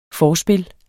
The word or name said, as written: FORSPILLETS